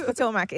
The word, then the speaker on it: Potomac: It's Potomac.